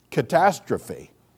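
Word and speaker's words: catastrophe (καταστροφή): You know, the Greek word, and this is how you pronounce the Greek word for ruin. You pronounce it catastrophe. You ever heard of that word? Yeah, that's our English. We took it right out of the Greek.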